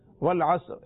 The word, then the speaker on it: والعصر: والعصری, اگر ملا کر پڑھیں گے تو یہ ہے والعصری والعصری, لیکن چونکہ وقف کرتے ہیں لہذا پڑھیں گے والعصر, لیکن وقف نہ کرنے کی صورت میں را کے نیچے زیر پھر ضرور پڑھیے گا.